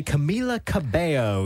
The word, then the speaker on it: Camila Cabello: Now, our guest this week, a very exciting one, actually, Camila Cabello. Yes, I'm pronouncing it correctly.